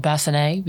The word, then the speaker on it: bassinet: Also, I believe it's called bassinet because it's French.